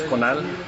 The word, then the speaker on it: Conal: Sí, mi nombre, primer nombre es Conal, se escribe C-O-N-A-L, apellido es Quetten, W-H-E-T-T-E-N.